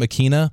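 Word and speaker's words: Ikhine: Nick Westbrook Akine, I think Akina. Is how you say his name. Akina.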